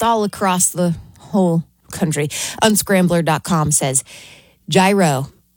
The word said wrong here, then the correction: gyro: Oh, I said it wrong right off the bat. Yiro takes the top spot.